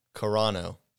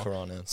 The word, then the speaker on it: Carano: We'll say it's Carano. – Yeah, that's what it is. Gina Carano. – Carano.